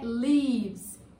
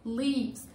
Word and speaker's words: lives: E aí a pronúncia é lives, lives.